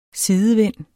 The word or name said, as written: sidevind